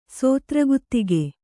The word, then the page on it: ಸುತ್ರಗುತ್ತಿಗೆ: sutra guttige